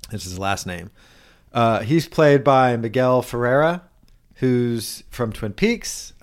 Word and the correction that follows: Miguel Ferrer: I butchered his name probably. Miguel Ferrer.